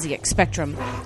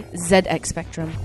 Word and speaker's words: ZX Spectrum: and ZX Spectrum. Or sorry, ZX Spectrum. I have to say it right.